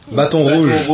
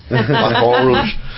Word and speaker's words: Baton Rouge: Baton Rouge. – Yeah, there we go. Baton Rouge.